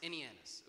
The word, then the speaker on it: Ananias: Saint Ananias. Yeah, or Ananias. Ananias is a better pronunciation.